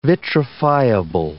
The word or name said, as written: vitrifiable